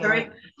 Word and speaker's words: Keurig: Yeah, you're saying Keurig.